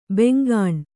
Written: ಬೆಂಗಾಣ್

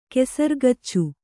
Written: ಕೆಸರ್ಗಚ್ಚು

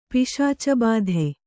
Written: ಪಿಶಾಚಬಾಧೆ

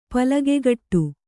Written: ಪಲಗೆಗಟ್ಟು

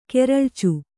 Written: ಕೆರಳ್ಚು

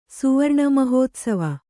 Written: ಸುವರ್ಣಮಹೋತ್ಸವ

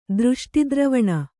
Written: ದೃಷ್ಟಿದ್ರವಣ

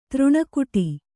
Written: ತೆರಣಿಗಟ್ಟು

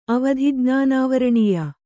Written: ಅವಧಿಜ್ಞಾನಾವರಣೀಯ

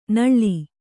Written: ನೋಳ್

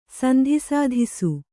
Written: ಸಂಧಿಸಾಧಿಸು

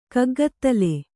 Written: ಕಗ್ಗತ್ತಲ್

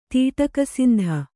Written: ತೀಟಕಸಂದಿ

